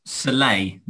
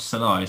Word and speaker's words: Saleh: It's Saleh, not Salah, isn't it? The Jets coach. I'm pretty sure. I'm 99% sure it's pronounced Saleh.